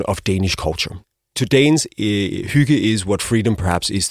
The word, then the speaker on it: hygge: It's spelled H-Y-G-G-E. – So it's pronounced hygge.